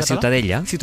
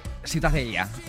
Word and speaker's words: Ciutadella: ¿cómo se pronuncia? – La ciutadella. – Ciutadella. Bueno, pues ciudadela también en castellano. – Ciudadela, sí. – Pues eso, ha quedado clarísimo. ¿Para qué se meten? – ¿Tú cómo lo pronuncias? – Ciutadella.